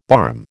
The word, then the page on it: barm: American English